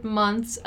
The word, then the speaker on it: month: This is another tricky word to pronounce, the word month. Not only because of the TH in the end, but because the letter O has a different sound as well. It's month, something like month, right?